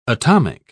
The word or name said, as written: atomic